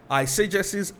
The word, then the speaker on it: eisegesis: You see, during the question and answer session at the end of last week's service, I mentioned that we will learn about eisegesis and exegesis. Now, what do these terms mean? One is spelled E-I-S-E-G-E-S-I-S. That's eisegesis. The other is spelled E-X-E-G-E-S-I-S.